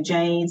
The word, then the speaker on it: Jayde: So LinkedIn is probably the best place, and it's just simply my name, so Jade, spelled with a Y, so J-A-Y-D-E, and then Phillips with two L's.